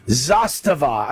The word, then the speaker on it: Zastava: Zastava.